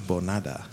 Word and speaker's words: Bonada: Bonada.